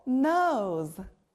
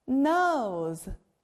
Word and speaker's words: nose: 바로 코, nose예요. 역시 O's 소리가 들리네요. 우리가 코로 다양한 냄새도 맡고 숨을 쉬어요. 코, 영어로 nose. nose.